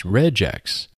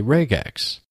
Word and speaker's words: regex: Some people might pronounce this as regex. I keep the hard G from regular and say regex.